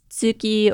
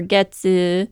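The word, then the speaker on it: tsuki (月): It's literally just the kanji for moon twice, which is pronounced tsuki or getsu or gatsu.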